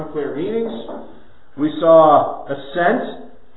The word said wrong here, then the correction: accent: altered meaning through changed emphasis. Accent, I said that wrong, right?